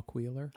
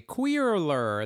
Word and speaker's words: Queeler: I feel like I sometimes say queerler and then I'm like queeler and then queeler. – No, it's not Queerler.